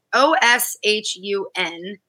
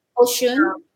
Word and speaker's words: Oshun: O S H U N. – Oshun.